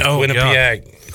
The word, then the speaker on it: Quinnipiac: And we, if anybody, should know to respect the 4 seed with Quinnipiac.